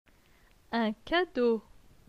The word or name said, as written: Un cadeau